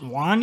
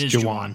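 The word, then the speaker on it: Jauan: is it Juan, you think, or is it Jawan? Jawan? – It's Jawan. – Jawan Jennings, displaying chemistry with Trey Lance in the offseason.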